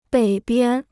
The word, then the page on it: 北边: běi biān